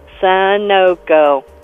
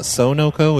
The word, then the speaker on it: Sunoco: Sunoco. – Oh, it's not Sonoco, it's Sunoco? – Yes.